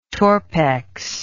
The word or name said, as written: torpex